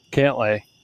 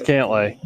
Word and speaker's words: Cantlay: I added an A in there, and there shouldn't have been. Cantlay. – Cantaloupe. – It's Cantlay. It's definitely Cantlay. There's an A that I added for fun.